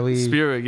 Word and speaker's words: Spierig: I said Spierig, but... Spierig is how we... – Spierig, yeah. Spierig. – How we said the official pronunciation.